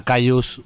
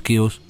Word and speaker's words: Kyuss: Así titula el programa de este sábado, en clara referencia al entorno en el que se crió la banda californiana Cayuse, pronuncia en inglés, aquí decimos Cuse.